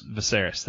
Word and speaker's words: Viserys: Is it Viserys? – Viserys, that's it.